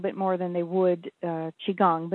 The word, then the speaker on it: Qigong: And Qigong is spelled kind of, it's Chinese, and it's spelled Q-I-Q-O-N-G, Qigong, but it's pronounced Qigong.